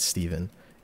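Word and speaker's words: Stephen: My name's Steven. It's spelled with a PHNA, so you think it's Stefan. Like a Frenchie, but it's not. It's Steven. And joining me, of course, is Steve, which is Steven spelled P-H-E, but we just say Steve.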